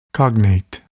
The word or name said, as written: cognate